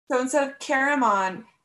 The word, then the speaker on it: Caramon: That's not how you say it. It's Caramon.